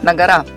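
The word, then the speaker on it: на-гора: И чтобы количество спусков равнялось количеству подъемов на гору. На гору, гора. Как правильно говорят, на гора.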